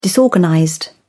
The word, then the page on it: disorganized: British English